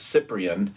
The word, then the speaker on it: Cyprian: Know that Cyprian, the name of this man, starts with a C, so it's a soft C. It's the plague of Cyprian.